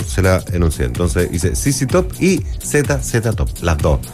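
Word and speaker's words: ZZ Top: Sissi Top, se pronuncia así, Sissi Top, nada más que eso, saludos.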